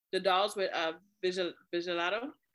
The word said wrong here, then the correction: vitiligo: Wait, is that how you actually say that word? – That's not how you say it, but I know what you mean. – No, okay. No, because I was like, that don't sound right. I thought it was vitiligo. – It's vitiligo.